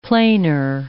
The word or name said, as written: planar